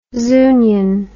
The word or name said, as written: Zunian